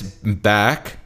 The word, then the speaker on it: Beck: It's Beck. Like you're back. Yeah. Although technically it's Peck.